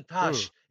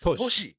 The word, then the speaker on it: Toshi: It's not even Tash. It's Toshi. Right? T-O-A. Yeah. But the they dropped I and it just became Tash. That's easier to pronounce, right? Or by mistake, somebody printed T-O-S-H instead of T-O-S-H-I. And then you just become Tash.